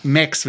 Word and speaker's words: M4XX: Ich glaube, Max wird es ausgesprochen, M4XX.